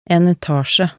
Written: en etasje

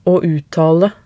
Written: å uttale

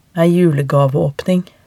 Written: ei julegaveaapning

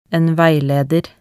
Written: en veileder

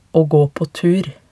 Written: aa gaa paa tur

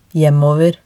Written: hjemover

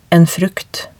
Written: en frukt

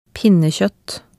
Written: pinnekjoett

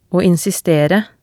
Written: å insistere

